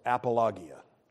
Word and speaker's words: apologia: The word for defense in the Greek is apologia, actually pronounced apologia.